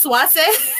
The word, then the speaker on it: Swayze: Apparently, his name in Spanish is pronounced Swayze.